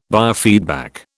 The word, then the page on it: biofeedback: British English